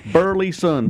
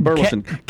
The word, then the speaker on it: Burleyson: And Captain Matt, do I pronounce it right? Burleyson? – Yeah. – How'd I do? – You did well. You did quite well. Yep. Burleyson. Burleyson.